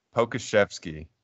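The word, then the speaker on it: Pokusevski: And they're sitting Dort and Shea Gilgeous and Pozeski. Is that how do you pronounce it? – Pokashevsky.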